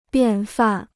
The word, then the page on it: 便饭: biàn fàn